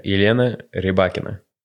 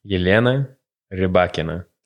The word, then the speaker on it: Yelena Rybakina: How do you pronounce Rybakina's name? – That was very good. Yeah, Yelena Rybakina. – Say that one more time. – Yelena Rybakina. – That's so good you say it a third time.